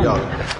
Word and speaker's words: Jung: It was Carl Jung. Carl Jung. Jung. – Good job on the pronunciation.